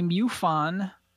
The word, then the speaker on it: MUFON: And I went to the MUFON website. – Thank you for pronouncing that correctly.